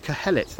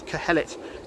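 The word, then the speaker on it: Qoheleth: Originally, the Hebrew word used is Kohelet. That's spelt with a Q at the beginning. So Q-O-H-E-L-E-T. Some translators put an H on the end of it. It's Kohelet is how I'm going to refer to him.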